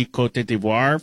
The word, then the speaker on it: Côte d'Ivoire: They requested to the UN, I think in the 90s, someone said that, hey, could we just be Côte d'Ivoire for everybody? And the UN was like, shut up. Yeah, that's the French pronunciation.